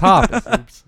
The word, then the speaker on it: Hoppus: Oh, well, it's Hoppus. That's not offending the guy. I just people pronounce things different ways.